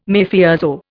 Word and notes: Mafioso: Indian

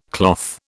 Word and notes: cloth: British English

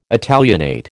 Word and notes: Italianate: American English

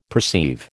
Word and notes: perceive: American English

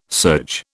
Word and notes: surge: British English

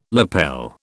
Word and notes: lapel: British English

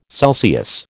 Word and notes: Celsius: American English

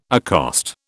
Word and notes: accost: British English

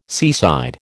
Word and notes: seaside: American English

